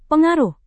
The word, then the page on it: pengaruh: Indonesian